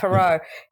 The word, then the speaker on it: Perreault: And his name is Paul Perreault, I'm going to say. I'm not sure I'm pronouncing his Perreault, yeah. – His name correctly. – Yep, Perreault.